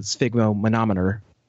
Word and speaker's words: sphygmomanometer: sphygmomanometer on hand. So it's interesting. – Yeah, it's a great book. And congratulations on nailing the pronunciation of that right out the gate.